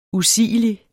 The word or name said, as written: usigelig